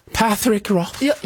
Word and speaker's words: Patrick Rothfuss: Patrick Rothfuss. – Jag tyckte det var perfekt. – Ja. Så hade han uttalat det själv.